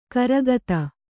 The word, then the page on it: ಗುರಿಗುತ್ತು: guriguttu